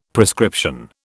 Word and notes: prescription: British English

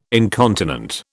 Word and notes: incontinent: British English

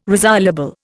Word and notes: resoluble: Indian

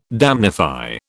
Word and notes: damnify: British English

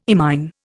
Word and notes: imine: Indian

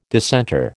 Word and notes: dissenter: American English

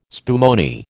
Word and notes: spumoni: American English

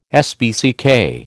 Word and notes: SPCK: American English

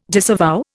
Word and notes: disavow: Indian

